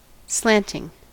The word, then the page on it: slanting: American English